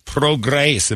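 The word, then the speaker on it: Prograis: Progress. Am I saying that right? I don't know these fighters. – Yeah, you are.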